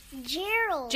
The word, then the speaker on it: Gerald: How do you say it, Gerard? – Gerald. – Gerald, sorry.